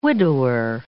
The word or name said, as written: widower